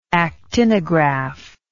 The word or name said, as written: actinographic